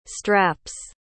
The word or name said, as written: straps